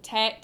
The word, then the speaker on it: Tadhg: His name is Tag. It's spelled T-A-D-H-G.